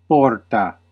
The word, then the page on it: gate: /ˈɡeɪt/; [ɡeɪt]